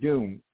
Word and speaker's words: Dume: But I lived on Point Doom here in Malibu, and it's spelled D-U-M-E, so not Doom, D-O-O-M.